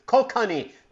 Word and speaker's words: Kokanee: Out west, they put bounties on them and pike because they eat their native cutthroat rainbows along with the K-O-K-A-N-E-E. You're the smart guy here. How do you pronounce that type of fish? – I have no clue. – Okay, well, at least pretend you do. Kokanee.